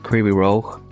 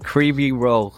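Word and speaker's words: Kryvyi Rogh (Кривий Ріг): I was born in Ukraine, a little town called, probably pronounce it wrong now, but Kryvyi Rogh. – Yeah. Say that again? – Kryvyi Rogh, but you gotta like... – Is that, what letters are that? – It's like G-H.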